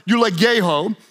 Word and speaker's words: eulogeo: Well, the word bless comes from the Greek word eulogeo, which means, it's spelled E-U-L-O-G-E-O. It's a little bit like eulogy.